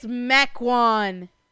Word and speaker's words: Mequon: It's Mequon. – Mequon. – It's pronounced Mequon. Not Mequon. It's Mequon. – Spell it differently. – I agree, but that's not the point.